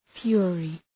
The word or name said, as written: fury